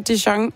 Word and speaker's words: Dijon: Det er den amerikanske kunstner Dijon. Og ja, det er navnet, du udtaler, ligesom din Dijon Senap.